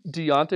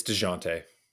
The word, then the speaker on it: Dejounte: Is is it Deontay or is it Dejounte? – It's Dejounte. It is Dejounte. Yeah. Soft Dejounte. It's a je. It's a je. It's a French. It's Dejounte.